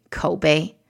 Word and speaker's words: Koubei: Kobe, that's spelled K-O-U-B-E-I.